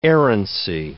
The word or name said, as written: errancy